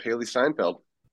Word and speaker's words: Steinfeld: Haley Stanfield. – Haley Stanfield. – That's our young Kate Bishop, Haley Steinfeld. – Haley Steinfeld, yep. – Yeah, Steinfeld.